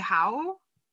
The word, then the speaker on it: Howe: But it's Sir Geoffrey Howe. Is that how you say it? – Howe? Yeah, he was her prime minister.